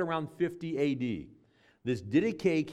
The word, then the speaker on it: Didache: And it's called the Didache or the Didache. That's how it's pronounced.